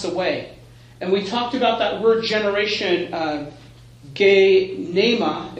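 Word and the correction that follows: Genea (γενεά): if I'm saying that right. – Genea. – For some reason, I think there's an M in there. Genea, which has to do with more than just generation.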